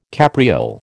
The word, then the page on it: capriole: American English